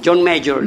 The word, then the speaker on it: John Major: No confundir con John Mayer. – John Major. Major. Se escribe Major.